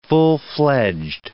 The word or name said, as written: full-fledged